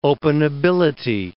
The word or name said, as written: openability